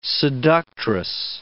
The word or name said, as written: seductress